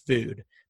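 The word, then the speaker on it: Füd: But the review was for a vegan restaurant in the West Side called Food, but it's spelled FUD, like F-U-D, but the U has the two dots over the top. So I think it's a long U. Mm-hmm. And so I think it's actually pronounced Food.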